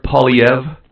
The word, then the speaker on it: Poilievre: Yeah, well, it's French, so it's I don't think he's French, but the name's French. Pierre Poilievre. – Poilievre. – Sort of a weird R, silent R.